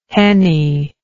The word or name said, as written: henie